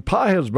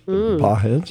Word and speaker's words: Pages: Páez, by the way, I could see where that could be mispronounced because it's spelled P-A-G-E-S, like right. Pages. Páez, just throw that at you.